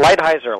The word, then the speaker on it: Lighthizer: Is it Lighthizer or Lighthizer? – You got it, Lighthizer.